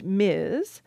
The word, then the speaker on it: Ms.: and they say it would be pronounced Ms. It pops up a few times over the next few decades.